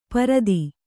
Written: ಪಾರುಧಿ

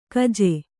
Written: ಗೂಜು

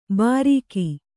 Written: ಬಾರೀಕಿ

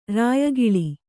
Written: ರಾಯಗೋಲು

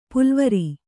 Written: ಪುಲ್ವರಿ

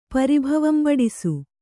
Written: ಪರಿಭವಂಬಡಿಸು

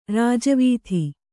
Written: ರಾಜವೀಥಿ

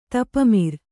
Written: ತಪಮಿರ್

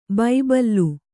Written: ಬಬ್ಬುಲಿ

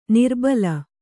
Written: ನರಬಲಿ

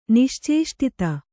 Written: ನಿಶ್ಚೇಷ್ಟಿತ

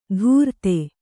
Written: ದೂರ್ತೆ

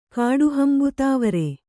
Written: ಕಾಡುಹಂಬುತಾವರೆ

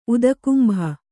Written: ಉದಕುಂಭ

